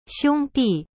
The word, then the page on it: 兄弟: xiong di